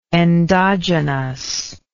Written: endogenously

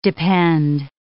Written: depend

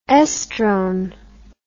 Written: oestrone

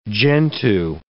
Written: Gentoo

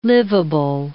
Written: liveable

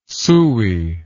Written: sooey

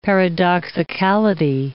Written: paradoxicality

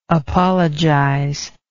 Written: apologizer

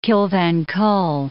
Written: Kill Van Kull

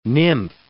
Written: nymph

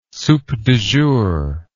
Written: soup du jour

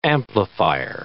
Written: amplifier

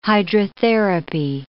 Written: hydrotherapy

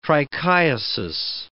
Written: trichiasis

